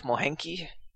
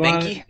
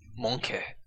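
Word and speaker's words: Moehnke: No, but you're not alone in pronouncing it that way. I've heard it pronounced that way dozens of times. – Well, just enlighten the world. How do you pronounce it? – Just call it Minky. If you Minky? – If you go to Germany and pronounce it, then it's Munke.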